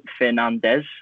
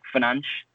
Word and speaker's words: Fernandes: So the Portuguese pronunciation is a little bit difficult because obviously in England, we, even with the surname, as English speakers, we're tempted to say as in Fernandes, as in like a three-syllable word. Whereas in Portuguese, they pronounce the Fernandes as like Fernanche. So they wouldn't really pronounce the Des.